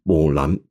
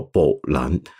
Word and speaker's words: 木兰: 陈三平认为应该很有关系，因为中古汉语里面的木兰的发音啊，跟我们今天不太一样，它比较像是今天你在闽南话里面，你上网查查看，看得到闽南话里面怎么去读木兰词，你会发现它木兰这个发音是这样的，木兰。这个字呢，虽然也有 m 这个音，但是隐隐约约你听得到“ 宝兰”。